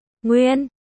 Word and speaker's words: Nguyên: Tiểu Nguyễn ca ca, cô nhóc không biết cách đọc đúng của từ. Nguyên, Kỷ Nguyên khẽ mỉm cười.